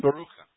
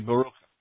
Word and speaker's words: Baruch: In the Hebrew, if you look at your name phonetically, it's mm-hmm. – Baruch. It's your name pronounced in Hebrew phonetically off of your spelling would be Baruch.